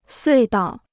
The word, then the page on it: 隧道: suì dào